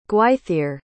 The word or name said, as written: Gwythyr